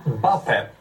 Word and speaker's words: Mbappé: Ja, Mbappé, rätt uttal.